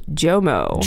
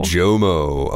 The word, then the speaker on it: JOMO: Yeah, it's actually pronounced Jomo. – Jomo.